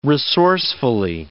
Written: resourcefully

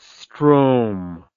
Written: stroam

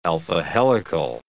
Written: alpha-helical

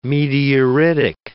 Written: meteoritic

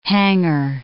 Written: hanger

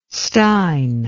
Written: stein

